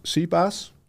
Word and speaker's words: CPaaS: Maar eh, de, grootste is CPA's. Zeg ik dat goed? Ja.